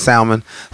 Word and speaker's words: salmon: My favorite fish is salmon. So, and I love it so much. I pronounce the L. A lot of people.